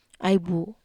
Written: ai bu

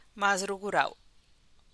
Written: maskaly

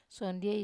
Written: sondiei

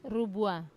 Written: rubua

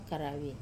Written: karavi

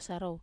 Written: sarou

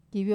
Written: kavio

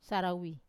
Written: sarawi

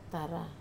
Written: tara

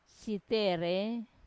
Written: sitere

